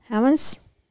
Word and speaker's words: Hamas: Hamas? Mm-hmm. That's how you say it? Mm-hmm.